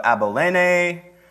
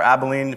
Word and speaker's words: Abilene: tetrarch of Abilene, or Abilene, if you want to mispronounce that like a Texan, during the high priesthood of Annas and Caiaphas, the word of God came to John son of Zechariah, in the wilderness.